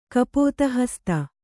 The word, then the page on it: ಕಪೋತಹಸ್ತ: kapōtahasta